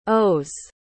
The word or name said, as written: ose